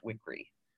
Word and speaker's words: Wickre: My last name is pronounced Wickery. – Wickery, thank you. Apologies. Yeah, just like it looks, which is what throws people.